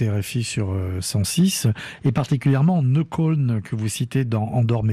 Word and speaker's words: Neukölln: Oui, j'ai habité à Neukölln quand je suis arrivé. – Vous prononcez mieux que moi. – Neukölln, on prononce.